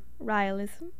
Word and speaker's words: Raelism: Raelism. We looked up how to pronounce it, and there were multiple ways to pronounce it, so we're going to stick with Raelism.